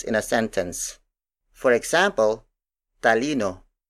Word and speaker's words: talino: Talino. Talino. Talino is spelled as T A L I N O. It means intellect, the faculty of reasoning and logic, which is different from emotions. Again, the Tagalog word for intellect is talino.